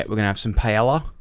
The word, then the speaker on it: paella: Well, it's, everyone knows it's two L's is a Y. It's paella.